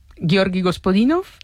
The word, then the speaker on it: Gospodinov: precisamente de Gospodino. – Hola. – Hola Pedro, hola Marta. – Ayúdanos a pronunciar los autores. – A ver, Georgi Gospodinov, ¿sí? – Zdravka Eftimova y Vera Mutavcheva.